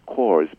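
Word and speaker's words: StoryCorps: And it's pronounced cores, but it's pronounced store. It's spelled story corpse. – So Yep, C-O-R-P-S.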